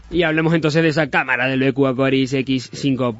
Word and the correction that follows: Aquaris: Lo he dicho bien, Aquaris, ya no he dicho Aquarius.